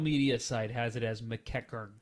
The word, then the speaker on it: McEachern: How did you say that? – Mackenzie McEachern. McEachern. Because that's the way it's pronounced and spelled.